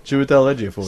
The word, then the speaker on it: Chiwetel Ejiofor: starring Chiwetel Shabu-Shabu. – You say his name, motherfucker. Stop it, Chiwetel Ejiofor.